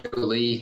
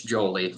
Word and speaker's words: Jolie: It's not Joe Lee. It's Jolie, like one you know, one smooth name, no hyphen in there.